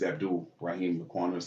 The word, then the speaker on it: Abdul Raheem Laquano: So, not as a correction of Barry, but for the correct pronunciation of my name is Abdul Raheem Laquano Sr. So just want everybody to know that. And I, you know, I went for Raheem so long, but it's actually Abdul. So Abdul Raheem.